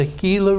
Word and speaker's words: Gila: And then we would go to the Gila River, spelled like Gila Monster, G-I-L-A, that ran through their reservation, going to the Gila River to baptize.